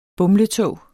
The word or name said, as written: bumletog